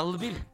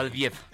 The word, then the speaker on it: Lviv: Qué Fíjense que Estados Unidos decidió trasladar su embajada en Ucrania de Kiev, que es la capital ucraniana, a Lviv o Lviv. Te puedo pronunciar de las dos maneras